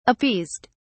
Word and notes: appeased: British English

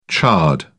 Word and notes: chard: British English

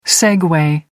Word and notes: segway: American English

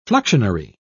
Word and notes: fluxionary: British English

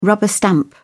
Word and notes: rubber stamp: British English

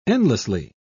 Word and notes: endlessly: British English

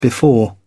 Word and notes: before: British English

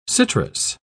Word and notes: citrous: British English

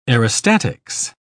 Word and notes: aerostatics: British English

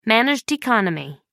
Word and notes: managed economy: American English

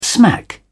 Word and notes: smack: British English

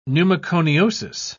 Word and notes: pneumoconiosis: British English

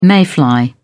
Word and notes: mayfly: British English

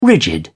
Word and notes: rigid: British English

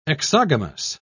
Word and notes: exogamous: British English

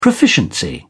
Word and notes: proficiency: British English

